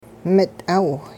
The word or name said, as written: mit'aaw